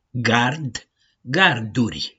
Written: GARD

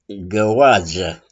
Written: GĂOADZĂ

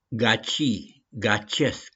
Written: GACI-GACIESC

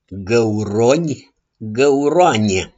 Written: GĂURONI

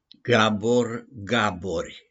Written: GABOR-GABORI

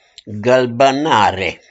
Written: GĂLBĂNARE

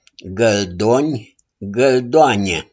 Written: GĂLDONI

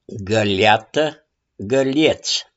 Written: GĂLIATĂ

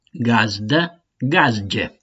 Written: GAZDĂ

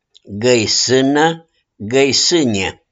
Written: GĂISÂNĂ

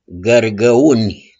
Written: GĂRGĂUNI

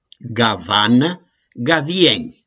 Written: GAVANĂ

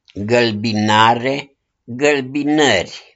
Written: GĂLBINARE

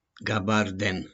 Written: GABARDEN

